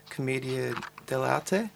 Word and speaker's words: Commedia dell'arte: Just to move on to 2006 and Folly and Grief, which explores the traditions of Commedia dell'arte, and I can't pronounce that at all well.